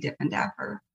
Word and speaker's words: Diffendaffer: And I'm also on LinkedIn and Instagram under my name, Stacey Diffendaffer.